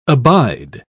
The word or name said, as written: abide